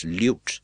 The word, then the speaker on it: lute: And fortunately, another actor who was there said, what he wants you to say is lute.